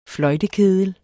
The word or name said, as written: fløjtekedel